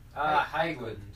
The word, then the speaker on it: Haglund: Haglund. That's how Highland? You pronounce it. Haglund. It's a Swedish surname.